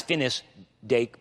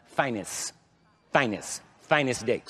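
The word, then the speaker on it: Finis Dake: I referred to him as Finnis, F-I-N-I-S, Finnis Dake, but his name is supposed to be pronounced Finnis Dake.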